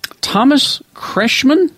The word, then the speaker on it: Kretschmann: Thomas Kretschmann, is that how you say his name? – Uh-huh.